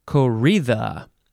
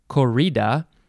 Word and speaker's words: corrida: Next is corrida. It is spelled, well, an English person, an American person would say corrida, but it is a Spanish word, so it is corrida. C-O-R-R-I-D-A.